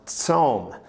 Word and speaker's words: tsom: In Hebrew, the word fast or fasting is pronounced tsom, and that means to abstain from eating.